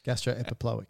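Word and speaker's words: gastroepiploic: And to go with your blood vessels in that greater omentum is the two blood vessels being the gastro-mental or gastro-epiplotic. – It was worth it just for the mispronunciation of gastro-epiploic.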